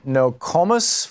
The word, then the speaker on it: Nokomis: Norman in Nokomis, Florida.